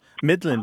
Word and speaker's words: middlin': Middling, by the way, just for everyone, is we're not pronouncing the G at the end, but it looks like middling, mm-hmm. M-I-D-D-L-I-N. Middling.